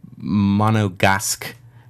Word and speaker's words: Monegasque: What I find very fascinating is that according to the Monegasque, that's how they pronounce the name, that's the demonym, the people who live there, the Monegasques.